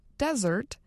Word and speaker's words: Desert: Today, locals still say the word desert, similar to its French pronunciation, Desert.